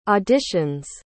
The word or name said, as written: audition's